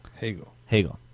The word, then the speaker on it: Hegel: Hegel. – Okay, thank you. For Plato, the immediate knowledge of the absolute is not bound to any special viewpoint. For Goethe, nature serves to illustrate the universe. For Hegel, history becomes a source of divine revelation.